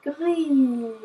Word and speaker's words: grün: Like, and then you will end up with grün. – Exactly. Yeah. Grün.